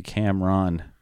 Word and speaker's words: Cameron: My name's Cameron, so it's a weird name. Cameron. I got room to talk. you know, yeah, it is actually supposed to be Cameron. I was like English proper pronunciation or whatever I was told by my English teacher, cousin, very young.